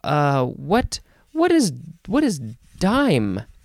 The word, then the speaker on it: disme: I guess they pronounced it dime spelled D I S M E um yeah, interesting.